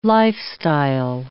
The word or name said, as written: lifestyle